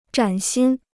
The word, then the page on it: 崭新: zhǎn xīn